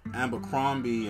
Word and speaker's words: Abercrombie: And y'all niggas are going fucking Ralph Lauren and fucking Abercrombie and fucking Hollister and all these fucking... – How do you say it? Amber what? – Abercrombie.